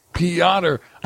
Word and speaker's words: Piotr: The R is silent, people. – Well, it's almost silent, but whatever the fuck you want to call it. – Piotr.